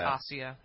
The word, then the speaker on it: Asia: It looks like Asia, but it's Asia.